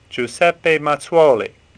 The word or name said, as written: Mazzuoli